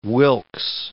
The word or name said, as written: Wilkes